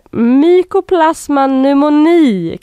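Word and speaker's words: Mycoplasma pneumoniae: sen så kommer vi till den här eh, svåruttalade bakterieinfektionen som heter Mycoplasma pneumoni kanske. Mm. Mm. Ja. Bra uttalat!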